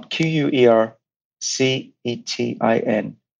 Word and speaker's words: quercetin: Um, Q U E R C E T I N, quercetin.